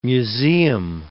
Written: museum

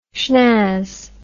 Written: shnazz

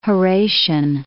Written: Horatian

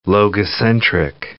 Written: logocentric

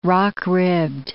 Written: rock-ribbed

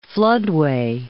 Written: floodway

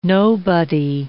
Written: nobody

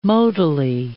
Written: modally

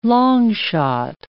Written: long shot

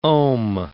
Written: ohm

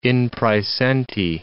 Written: in praesenti

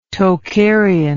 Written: Tokharian

